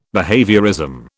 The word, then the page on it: behaviourism: British English